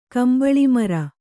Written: ಕಂಬಳಿಮರ

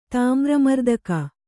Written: ತಾಮ್ರಮರ್ದಕ

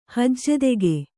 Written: ಹಜ್ಜೆದೆಗೆ

